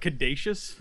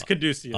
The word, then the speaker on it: Caduceus: It's not Caduceus. – No, I don't know. – It's Caduceus.